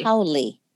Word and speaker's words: Haole: Is that how I pronounce that? Haole. – Haole.